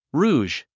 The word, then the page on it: rouge: rúːʒ; ルージュ